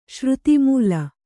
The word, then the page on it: ಶ್ರುತಿಮೂಲ: Kannada